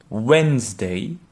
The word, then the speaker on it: Wednesday: Par exemple, euh, le mercredi se prononce en anglais Wednesday. il y a plusieurs lettres qui ne se prononcent pas si tu as l'écrit sous les yeux.